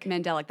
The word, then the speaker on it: mandelic: Mandelin. – Yeah, those are all types of... – Is that how you say it? Mandelic. Mandelic. – Mandelic. That's the apple one.